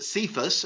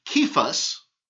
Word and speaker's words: Cephas: You shall be called," generally in the English, we pronounce it Cephas. In the Greek, it would be Kephas.